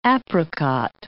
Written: apricot

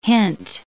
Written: hint

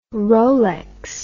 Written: rolex

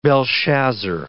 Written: Belshazzar

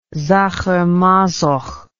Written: Sacher-Masoch

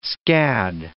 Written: scad

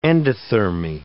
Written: endothermy

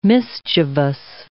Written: mischievous

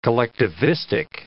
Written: collectivistic

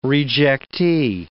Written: rejectee

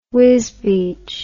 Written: Wisbech

